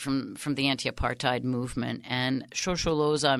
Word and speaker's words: Shosholoza: I'm going to try to pronounce it Shosholoza. – Mm-hmm, that's right.